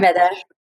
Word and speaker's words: Ayurveda: Am I saying it right? Mm-hmm. Ayurveda? – Yes, okay. Ayurveda, yeah.